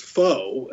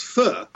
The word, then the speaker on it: pho: It's Q-U-A-Y, but it's pronounced K-E-E. But what about P-H-O? – That's an interesting one. I always thought it was faux until I started doing this book, and it's correctly pronounced fu.